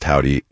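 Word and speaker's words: Hovvdy: They spell Howdy with two V's, of course, two V's instead of the W, so it kind of looks like Hofty, but pronounced Howdy.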